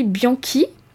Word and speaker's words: Bianchi: J'ai envie de vous partager le travail d'Aurélie Bianchi. Je pense que ça se prononce comme ça, c'est B-I-A-N-C-H-I,